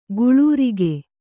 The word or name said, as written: ಗುಳೂರಿಗೆ